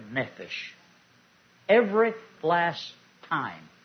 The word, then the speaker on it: Nephesh: Nephesh. Pronounced N-E-P-H-E-S-H. Nephesh.